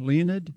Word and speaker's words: Leonid: This band called Leonid and Friends. – Yeah, I'd never heard of them until you brought them up. – Is it like the meteor shower, Leonid? – Yeah, it is. Okay. That's probably the way to pronounce it, too. L-E-O-N-I-D.